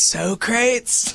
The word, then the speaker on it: Socrates: I just wish you say his name right. It's Socrates.